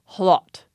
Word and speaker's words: hlot: So I thought we'd back up to the Old English and the word in Old English that is pronounced hlot. It has an H on the front. That combination H-L was possible in Old English. A loaf of bread was a hlaf, H-L-A-F.